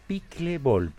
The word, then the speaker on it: pickleball: Pickleball. ¿Se pronuncia? ¿Cómo se pronuncia esto? ¿Cómo es? – Pickleball. – La... ¿Pickleball? ¿Así? Pickle. Como pickle, como el pickle.